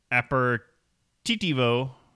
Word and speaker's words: aperitivo: It is pronounced aperitif in French and aperitivo in Italy.